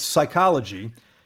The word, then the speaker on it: psychology: Don't let your imagination go wild, but it's psychology, and the P is silent.